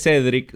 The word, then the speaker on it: Cedric: Mi nombre es Cedric, se escribe C-E-D-R-I-C.